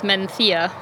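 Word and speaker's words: Mencía: Well, Mencia. Oh. I don't say it right. It's Mencia, right? – Sure, but Mencia, it sounds really weird.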